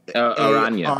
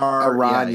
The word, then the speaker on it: Aranya: It's Anya, yep, right? And I also loved Aranya. – Aranya.